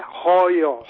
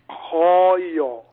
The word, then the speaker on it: hooyo: It is the word H-O-O-Y-O. Is it Hoyo? – Yeah, Hoyo, yes. – Say that again. – Hoyo.